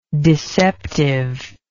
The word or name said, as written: deceptiveness